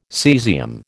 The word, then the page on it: cesium: American English